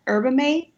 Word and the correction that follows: yerba mate: Is that how you say it? – Yerba mate? – Yerba mate.